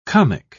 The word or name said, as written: comic